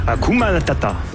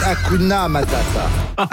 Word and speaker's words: Hakuna Matata: Hakuna Matata. – Non, c'est Hakuna Matata. – Hakuna Matata. – Mais non, mais oui, mais. – Oh, le bâtard, il en fait perdre la cause. – Hakuna Matata.